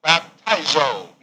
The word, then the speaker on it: baptizo: is baptizo, B-A-P-T-I-Z-O, long O, baptizo.